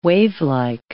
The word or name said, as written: wavelike